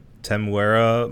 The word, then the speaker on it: Temuera: Teramu Morrison or something like that. Mm. Look it up for me, please. I don't want to butcher the guy's name. Um, Temuera Morrison.